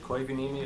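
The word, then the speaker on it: Koivuniemi: My name is David Koivunimi. It's spelled K-O-I, V as in Victor, U-N-I-E-M-I.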